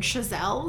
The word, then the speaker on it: Chazelle: Interestingly, I just read a little bit about the movie, and Damien Chazelle, is that how we say his name? – Yeah, the writer-director of the film, wrote it initially as a thesis for his, like, Harvard class.